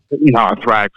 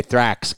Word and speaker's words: Thrax: Thrax, like spelled like anthrax. – Oh, okay. Thrax.